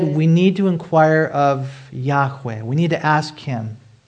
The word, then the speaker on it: Yahweh: It could be uh, Yahweh or Yahweh. It's not Jehovah because there's no J sound in Hebrew, but it's God's personal name.